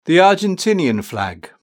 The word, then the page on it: the Argentinian flag: British English